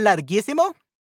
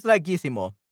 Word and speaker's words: larguísimo: Larguísimo is also esdrújula. – Larguísimo. Sí, so it doesn't have the tilde. It's, at, it's in a vowel, but it doesn't have the tilde at the last syllable or at uh, the second to last, but at the third to last.